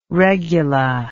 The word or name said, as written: regula